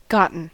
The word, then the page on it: gotten: American English; GenAm; IPA: /ˈɡɑʔn̩/